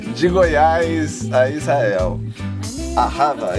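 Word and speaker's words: Ahava (אהבה): Nosso maestro, consultor de hebraico, está nos corrigindo, dizendo que a pronúncia correta seria Ahava.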